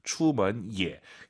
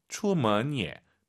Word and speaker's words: 出门也: 추먼 예. 예가 올라가면 안 돼요. 예 올라간 순간 틀린 거죠. 뒤에가 일성이니까 반삼성이에요. 추먼 예, 方便. 예, 方便. 그리고 앞에 있는 먼 이성은 끌어올려야 됩니다. 추먼 해도 알아듣지만 추먼 예.